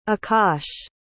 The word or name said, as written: akash